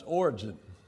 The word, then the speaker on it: Origen: And two major figures, we can look back and see, two major figures in regard to that, and that is Origen. That's pronounced Origen.